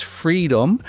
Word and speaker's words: FRDM: Today, he is the CEO and founder of a company called FRDM, pronounced Freedom,